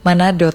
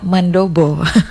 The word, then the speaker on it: mandobo: Waktu awal-awal saya bacanya bubur Manado, ternyata mandobo.